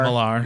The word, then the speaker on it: Millar: Mark Millar, the writer, not Mark Millar. – Not Millar. – Even though it's spelled with an A.